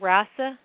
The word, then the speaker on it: rasa: The first tissue is called rasa. It's spelled R-A-S-A.